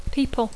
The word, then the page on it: people: English